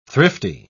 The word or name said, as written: thrifty